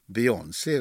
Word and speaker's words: Beyoncé: Beyoncé är väl det rätta? – Ja.